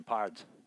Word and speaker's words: Pards: P-A-R-D-S. – Yeah, Pards. – West Texas. Yeah. Short for partner. – Yep. – But somehow there's a D in there when you say it like West Texan, pods. Hey, pods.